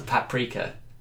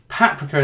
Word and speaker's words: paprika: So my godfather once said to me, if in doubt, the general rule is the emphasis goes on the first vowel. – Yeah. – So in that, yeah. is right. So paprika in that, but whenever I say that, people say no, it's paprika.